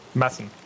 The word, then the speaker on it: Madsen: Yes, Madsen. Madsen. – Yeah. – It's a soft D.